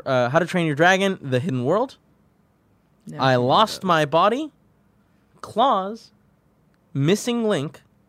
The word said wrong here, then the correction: Klaus: Really quick, I think that's supposed to be Klaus. – What's that? – It is Klaus.